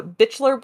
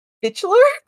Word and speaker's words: Bichler: I'm sorry, the what brothers? – Bichler? B-I-C-H-L-E-R. – I really hope that's how you say it. – I hope so, too. Maybe it's Bichler? – Bichler. You're being a real Bichler.